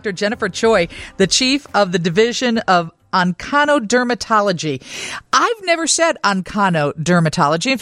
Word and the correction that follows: oncodermatology: It's actually pronounced oncodermatology.